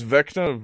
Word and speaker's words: Vecna: This cursed hand, also known as the Hand of Vesna, adds a chilling 2d8 Vesna? – Cold damage. – There's also... He pronounces Vecna Vesna the entire video.